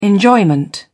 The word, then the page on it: enjoyment: British English